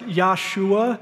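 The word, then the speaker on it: Yeshua: It probably was more accurately pronounced like Yahshua in Jesus' day.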